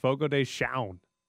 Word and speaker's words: Fogo de Chão: It's C-H-A-O is how the word is spelled. And yesterday, when I did it, I said Shown, but I was like, that cannot be right. I just went and looked it up on this guy on YouTube saying, here's how you pronounce it. And it's got an N on the end.